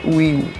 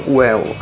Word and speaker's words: will: A pronúncia, apesar da, da palavra ela ser soletrada W-I-L-L, mas a pronúncia você não tem ali um I, a letra I, com o mesmo som de I, como é em português. Então a pronúncia não é will. Também não é um, um, um som well, não é um som de E. Então ficaria ali no intermediário, no meio do caminho, seria will. I will study English, tá certo?